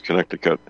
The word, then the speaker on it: Connecticut: It is Connecticut, and you're right.